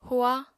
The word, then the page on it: hua: apatani